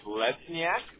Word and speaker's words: Lesniak: So my first name is Nicholas, and then my last name is Lesniak. And that is spelled L-E-S-N-I-A-K.